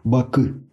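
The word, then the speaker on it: Bakı: Вот меня учили азербайджанцы произносить э, Баку, она а азербайджанском. Но не знаю, насколько я э, преуспел. Бакы, Бакы, они как-то так вот говорят.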